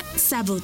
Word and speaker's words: зовут: Меня зовут. Por ejemplo, меня зовут. Esta palabra se escribe меня. M-I-Ñ-A. Меня. Зовут. Z-A-V-U-T. Зовут.